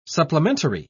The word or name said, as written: supplementary